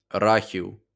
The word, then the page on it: Рахів: [ˈrɑxiu̯]; Ukrainian